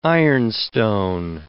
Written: ironstone

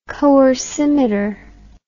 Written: coercimeter

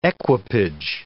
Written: equipage

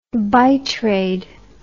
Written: bitrade

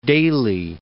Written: Daly

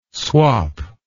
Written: swop

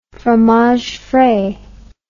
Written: fromage frais

